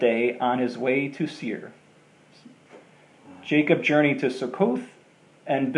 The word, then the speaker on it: Seir: until I come to my Lord at Seir.